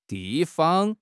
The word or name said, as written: di4 fang5